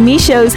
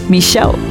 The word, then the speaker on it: Michaud: "Hi, as I'm from Louis Michaud's hometown, his last name is pronounced Michaud. Just thought you'd like to know." Well, Raymond, we did check on the pronunciation before the interview, but it was nice to have you as backup because originally we did think it was pronounced Michaud.